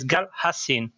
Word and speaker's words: Gal Hassin: The right pronunciation is Gal Hassin, because Hassin is the former Arabic name of Isnello, which is the little village where the center sits, and which means called the creek.